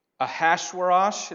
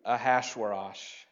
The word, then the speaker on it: Ahasuerus: Xerxes, this guy named Ahasuerus. It doesn't look like that, but I'm told that's how it's pronounced. I feel weird too. Ahasuerus.